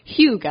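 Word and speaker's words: Hygge: It's Hugo, as in hue, as in the color, I guess, a hue. – Or Hugh Grant. – Or hue, as in Hugh yeah. Jackman.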